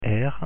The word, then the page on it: r: French